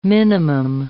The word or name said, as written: minimum